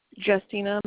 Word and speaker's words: Jestina: Justina Miles. – Can you spell that for me, please? – Justina. – Justina. – J-E-S. J-E-S-T-I-N-A. – J-E-S-T-I-N-A.